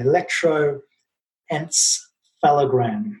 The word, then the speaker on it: electroencephalogram: Electroencephalogram.